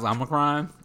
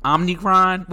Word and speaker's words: Omicron: I hate when people cannot pronounce Omicron. And yeah. I get everybody's not Greek. They don't know the Greek alphabet. Delta is a Greek letter.